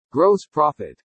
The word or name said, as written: GROSS PROFIT